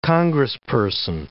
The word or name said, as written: congressperson